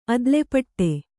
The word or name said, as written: ಅದ್ಲೆಪಟ್ಟೆ